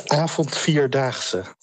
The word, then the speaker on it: Avond Vierdaagse: You've been doing the Avont Vier Dunks. Is that how you pronounce the festival? – The Avond Vierdaagse.